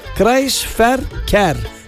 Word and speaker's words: Kreisverkehr: Okunuşu da Kreisverkehr.